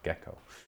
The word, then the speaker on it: gecko: Gecko, thank you, Kyle. I was not sure how to pronounce that. Gecko. I'll remember.